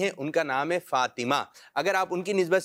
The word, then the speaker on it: فاطمہ: اس کا درست تلفظ فاطمہ, یعنی توا کے نیچے زیر ہے,